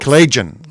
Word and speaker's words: Collagian: Collagian? – Collagian Blunt. – Collagi or Collagian? – Collagian, three syllables. – Collagian.